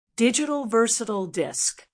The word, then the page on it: digital versatile disc: American English